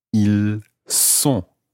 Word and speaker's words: ils sont: So that time it's an S sound, a s. Ils sont. – Ils sont. – So in that situation, you're not pronouncing the S of the il, but you're pronouncing the S of the word sont, S-O-N-T.